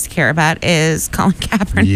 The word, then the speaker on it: Kaepernick: So they decided to have Colin, I can never pronounce this dude's name. – It's Kaepernick.